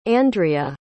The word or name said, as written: Andria